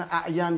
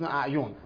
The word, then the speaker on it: اعین: مثلا ممکنه مثلا زرارت ابن اعین یا زرارت ابن اعیون، مثلا حالا اعین گفته میشه از یا اعیون میگن، نه یه غلط مشهوریه و این اصل چیزام در بحث سنان هم میفهمی سنانه.